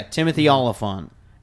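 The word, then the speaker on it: Timothy Olyphant: That voice actor who plays him, Timothy Oliphant is how I think you say his name. – Yeah, Timothy Oliphant.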